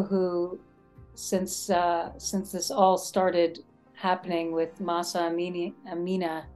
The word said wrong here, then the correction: Amini: was that how I say her name? – Amini, Mahsa Amini.